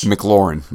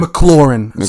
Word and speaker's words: McLaurin: McLaurin, my bad. – McLaurin. Sorry, I apologize. So McLaurin. And then, of course, Thompson coming through with 7 catches, 68 yards.